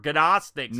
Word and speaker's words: Gnostics: Or Gnostics. – Gnostic. – The G is silent.